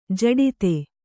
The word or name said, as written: ಜಡಿತ